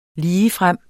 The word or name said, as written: ligefrem